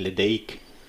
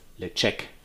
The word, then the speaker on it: Ledaig: Und da gibt es eben den Ledaig, also geschrieben L-E-D-A-I-G. Und ich würde ihn ins Englisch aussprechen, Ledaig, aber er heißt Lechek.